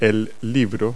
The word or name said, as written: book